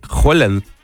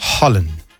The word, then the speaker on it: Haaland: Qué interesante, vi que en su idioma se pronuncia Holland. La doble A se pronuncia como O. – O sea, como Holland, como ajá. Holanda. – Ajá, ajá, ajá. – Holland.